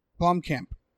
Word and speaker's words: Blomkamp: I don't think that, how do you say his name? Blomkamp? – Blomkamp.